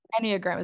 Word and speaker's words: Enneagram: We did the Enneagram. – Enneagram.